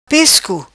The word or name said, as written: Pescu